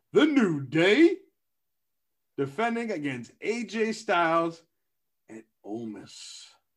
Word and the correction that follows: Omos: Omos, Omos.